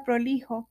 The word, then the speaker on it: prolijo: Esta palabra prolijo es una palabra llana, no se acentúa en la penúltima sílaba debido a que ésta termina en vocal.